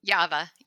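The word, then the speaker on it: Yava (Ява): Can you pronounce that factory? The Yava, right? – Yava, yeah.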